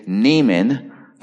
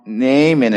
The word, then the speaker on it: Naaman: His name is spelled N-A-A-M-A-N, so when I used to read these passages to my kids, I'd say, Naaman. I just got to say that once to get it out of my system.